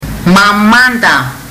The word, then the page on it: mamada: fiji